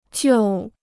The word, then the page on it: 救: jiù